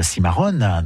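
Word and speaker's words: Cimarrón: Alors, cette exposition Cimarron, donc je la prononce à l'espagnol, puisqu'il faut le prononcer ainsi, est à voir jusqu'au dimanche 14 avril prochain, donc au Château des Ducs de Bretagne, au Musée d'Histoire de Nantes.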